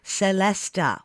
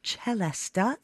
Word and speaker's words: celesta: It's called the celesta, which can also be pronounced celesta, if you like. But celesta is a very common way to say it. It comes from the French word céleste, meaning heavenly.